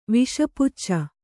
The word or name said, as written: ವಿಷಪುಚ್ಚ